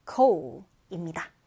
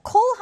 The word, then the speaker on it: coal: 자, 그 다음 보시면 이 단어 발음 주의해 주세요. O 발음에서요, coal, coal입니다. 자, coal 하면 석탄을 얘기해요.